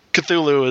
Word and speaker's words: Cthulhu: I guess even H.P. Lovecraft was never really clear on how to pronounce it. He mostly said it was, like, you're kind of clear in your throat. But, Cthulhu is the generally accepted version, I guess.